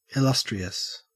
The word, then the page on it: illustrious: Australian English